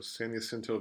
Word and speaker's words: San Jacinto: Anyway, they're hosting San Jacinto, or is it Jacinto? – I believe it's a silent J. – Okay, so San Jacinto Valley, that's a 4 p.m.